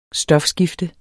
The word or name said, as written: stofskifte